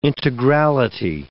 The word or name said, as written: integrality